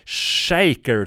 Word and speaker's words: Schacher: En trio som bestod av sångaren och gitarristen Mark Farner, trummisen Don Brewer och basisten Mel Shaker, tror jag man ska uttala hans efternamn. Det stavas S-C-H-A-C-H-E-R, så det kan ju lika gärna uttalas Schacher, men det är ju amerikaner, de brukar alltid förvränga allting så det ska låta lite häftigt, så Shaker säger vi.